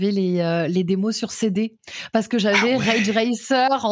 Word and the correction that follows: Ridge Racer: en démo comme ça. – Ridge, Ridge. – Ridge, pardon, ouais. Ridge Racer, avec ma prononciation excellente.